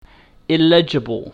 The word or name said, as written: illegible